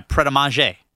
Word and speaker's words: Pret a Manger: Is that how you pronounce that? – Yeah, Pret a Manger.